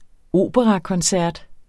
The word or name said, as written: operakoncert